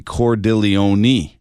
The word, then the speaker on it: Cordileone: According to EWTN's introduction of Reverend Salvatore Cordileone, Archbishop of San Francisco, his last name ends with a long E, so it'd be Cordileone.